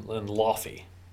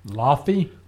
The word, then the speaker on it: Laufey: Lofi? – L-A-U-F-E-Y. – Okay. – I heard it pronounced on a couple different podcasts I listened to, and that's how they pronounced it, so I'm going with it.